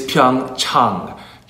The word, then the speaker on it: Pyeongchang: The correct pronunciation is Pyeongchang. – Chang. – Like when you go see your doctor. Pyeongchang. Pyeongchang. Pyeongchang. – Okay. – Pyeongchang. – So it's not Pyeongchang. It's Chang.